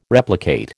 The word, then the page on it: replicate: American English